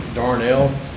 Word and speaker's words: Darnell: My name is Thomas Edward Darnell. Last name is spelled D-A-R-N-E-L-L.